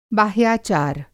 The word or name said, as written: बाह्याचार